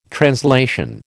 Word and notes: translation: American English

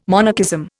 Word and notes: monarchism: Indian

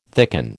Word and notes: thicken: American English

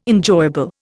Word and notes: enjoyable: Indian

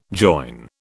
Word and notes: join: British English

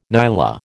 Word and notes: nyala: American English

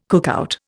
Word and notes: cookout: Indian

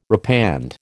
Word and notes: repand: American English

